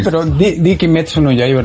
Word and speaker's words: Kimetsu no Yaiba: Ese, eh, ah, el último que, que pronunciaste no es tan difícil. Kimetsu no Yaiba.